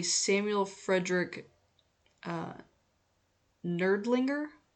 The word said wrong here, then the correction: Nirdlinger: May or may not have said that correctly. It's Nirdlinger.